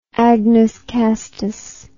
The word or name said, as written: agnus castus